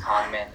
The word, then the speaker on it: Kangmin: But yeah, my name is Kangmin. It's spelled like K-A-N-G-M-I-N. I always say it's like Kingman, but you switch the vowels.